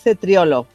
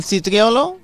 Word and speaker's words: cetriolo: Doncs el cetriolo... – Cetriolo, no? – Sí, se li ve el cetriolo. – Cetriolo, cetriolo. – Cetriolo. – Sí. – La C i la E es pronuncien che.